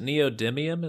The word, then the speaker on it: neodymium: Well, so is it neodymium? Is that how you say it? The magnets. – Yes, I think that's right. Or at least closest.